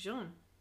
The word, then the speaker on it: jaune: Jaune.